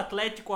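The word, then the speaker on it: Athletico: É, TH, como é que fala Atlético agora que tem H no meio? Não, o Rudy não sabe. Fala do mesmo jeito, Rudy.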